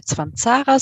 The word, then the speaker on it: Cvancara: Hallo, mein Name ist Brigitte Zwanzara, so wie du schon richtig ausgesprochen hast.